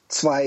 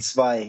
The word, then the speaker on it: Zwei: I think I pronounced the W as a W, which is my main mistake. I should have known. – Zwei, yeah. It's like a V.